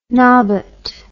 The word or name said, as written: nobbut